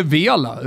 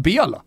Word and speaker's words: Vela: Det är Bela va? Det är inte Vela, utan det blir i Mexiko, jag har varit där mycket, där, där säger man Bela.